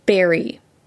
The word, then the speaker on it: Barre: For example, there is no faster way to out yourself as a newcomer than by mispronouncing the city next to Montpelier, the one that's spelled B-A-R-R-E. – Barry. Not bar. Not at all. Barry, more like blackberry or blueberry.